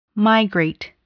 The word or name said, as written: migrate